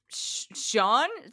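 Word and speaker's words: Sjón: I'm going to Sean, S-J-O-N. – S-J-O-N, yes.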